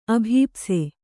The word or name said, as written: ಅಭೀಪ್ಸೆ